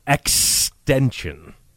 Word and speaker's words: XXXTentacion: I believe that's pronounced extension. – It's not pronounced XX, it's three X's. So why isn't it pronounced XXX? – So you say extension.